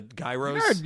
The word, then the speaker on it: gyro: G-Y-R-O. – Euro. – Euro? – For in Greek it is. – I know it's... – That's how they say it in Greek.